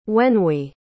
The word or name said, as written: wenhui